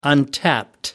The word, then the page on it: untapped: American English